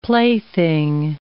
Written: plaything

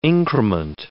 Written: increment

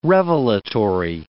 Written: revelatory

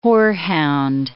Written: horehound

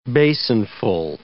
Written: basinful

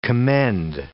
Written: commend

